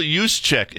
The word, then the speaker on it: Juszczyk: Kyle Juszczyk is his name.